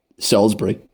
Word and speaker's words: Salisbury: This is we, we got the name of the town nailed on Salisbury. – Yeah, got that. That's fine. – However, the state came under a bit of a debate of how this was pronounced. So it's Maryland, Salisbury, Maryland.